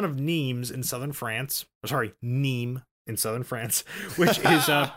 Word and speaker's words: Nîmes: Nîmes. – Yes, Nîmes. – Nîmes. – I just remember it rhymes with meme, and then I can do exactly.